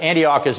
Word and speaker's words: Antiochus: It's pronounced, Antiochus is alright, but Antiochus, is pronounced Antiochus.